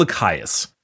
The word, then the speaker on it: Lycaeus: I'm gonna go Lycaeus. L Y C A E U S. Lachaeus.